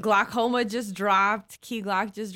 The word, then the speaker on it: Glockoma: And uh, the album is Glockoma. – It's called Glockoma. – That's and it's spelled like Glock the gun.